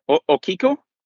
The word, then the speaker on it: Okiku: So the doll that you're talking about was Okiku? Okiku? – Yes. – Maybe is how it's pronounced? – Yes.